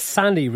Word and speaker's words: Sani: Now, you're going to talk a place called the Sani Resort. Is that how it's pronounced? – Yeah, that's right.